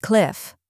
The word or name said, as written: cliff